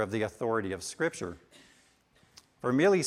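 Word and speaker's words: Vermigli: Next, Dr. Nichols introduces us to the character of Peter Martyr Vermilli. The G is silent.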